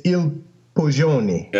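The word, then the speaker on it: Il Poggione: It's a Brunello di Montecolino, and I don't know how the double G is pronounced in Italian. Go ahead. – It's Il Poggioni.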